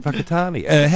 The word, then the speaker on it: Whakatāne: You've also got Shag Point and Whakatāne. How do you say that? – That WH. – Yeah, that's pronounced with an F.